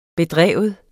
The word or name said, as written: bedrive